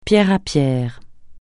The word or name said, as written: pierre à pierre pierre par pierre